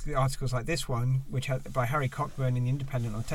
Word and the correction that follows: Cockburn: I think that name is usually pronounced Coburn.